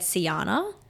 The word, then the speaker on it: Sjana: It's spelled SJANA, but it said Siana.